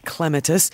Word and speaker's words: clematis: It kind of depends what, first, number one, how patient you are as a gardener, because clematis comes to mind, properly pronounced clematis.